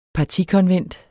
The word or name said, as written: partikonvent